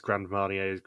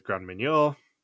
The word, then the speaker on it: Grand Marnier: Andrew then mispronounces Grand Marnier's Grand Meunier.